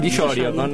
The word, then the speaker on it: Dillorio: ¿Cómo se dice? Dillorio. Con acento en la Y.